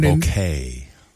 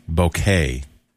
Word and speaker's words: bokeh: Bokeh. – What? – Bokeh. – As in a bouquet of flowers? – No. Just pronounced similar. Is it B-O-Q-U-E? – Yeah, I think so.